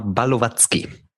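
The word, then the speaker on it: Balovatsky: Wladislav Balojewski. – Rapper. – Ja, Kapital Bra. Sorry, ich habe es auch falsch ausgesprochen beim zweiten Mal. Balowatzki.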